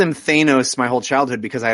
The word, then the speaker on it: Thanos: And it has a bad guy called Thanos, who I call Thanos, which I know is wrong. – Yeah, it's Thanos, like Van. – Right. It's a short, it's not a long A.